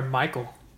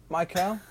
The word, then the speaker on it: Mikel: Is it Mikel or Michael? – Mikel? Mikel. – Looks like you could pronounce it Michael. Michael Franco. His American name. – You could definitely probably say Michael as well.